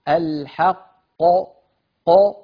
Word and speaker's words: الحق: الحق ق، انتبهتم؟ فلا نقول الحق الحق ق ق هكذا لبرة، إذا نطقنا القاف إلى الخارج مباشرة أين التفخيم؟ إذن بغير الحق ق، نضغط الصوت إلى قبة الحنك، هذا أمر.